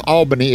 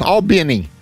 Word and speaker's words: Albany: I mispronounce Albany. It's Albany. Albany, Georgia. Albany.